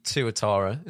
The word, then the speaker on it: Tuatara: So you were behind the wheel of the SSC Tuatara.